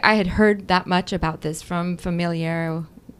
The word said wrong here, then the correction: Familiaris Consortio: Say it right? Familiaris Consortio.